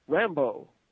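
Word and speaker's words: Rambeau: Rabu? Is that how you say it? – Rambo. – Really? That's Rambo? – Yes, that's how it's pronounced, yes.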